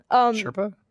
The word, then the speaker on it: Sherpa: the reversal where Asurpa... Is that how you say it? – Sherpa? – Yeah.